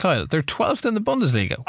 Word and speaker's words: Bundesliga: It's pronounced Bundesliga.